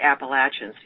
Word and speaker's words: Appalachians: I hope I don't embarrass you, but I have a review of Daughters of the Appalachians. See, I said it right that time. – And you did.